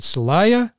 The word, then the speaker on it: Celaya: It's a remarkable endeavor to travel to a shelter in the central Mexican town of, I hope I pronounce this right, Celaya, to collect, yeah. – Oh, good, that's nice, to collect stories from immigrants.